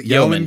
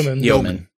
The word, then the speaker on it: Yeoman: Yeoman Kelly Chambers. – Yeoman.